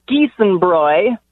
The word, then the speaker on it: Giesenbräu: It's Giesenbräu.